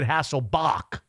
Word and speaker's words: Hasselbach: A guy by the name of Harold, and I think they pronounced it Hasselbach. Not bad. – Yeah, it was a C-H, right? – C-H. – Yeah, I saw that. – C-H, not any relation to Hasselbeck.